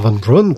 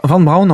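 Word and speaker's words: Von Braun: On va suivre donc un ingénieur qui part du centre d'essai qui est dirigé par Van Braun pour rejoindre la résistance et là. Alors, Van Brown, en fait, il, il, pas obligé de le prononcer à l'anglaise.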